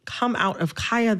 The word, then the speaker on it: Kaya: I am going to, in this conversation, pronounce Kaya, the rapper, with the long I.